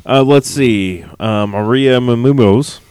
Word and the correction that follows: Menounos: However you pronounce her last name. – Maria Poopsaymunos. – Maria Menounos.